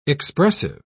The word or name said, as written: expressive